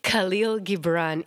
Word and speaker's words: Khalil Gibran: Khalil Gibran in The Prophet,